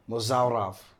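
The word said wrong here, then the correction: Mozharov: Something like Mosharov. – Yeah. – That's how you say it.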